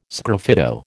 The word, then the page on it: sgraffito: American English